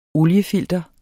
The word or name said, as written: oliefilter